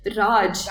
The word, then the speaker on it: Raj: And Raj. – Like, what is it with Americans and pronouncing the J in Indian names with like a J? It's not French.